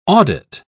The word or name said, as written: audit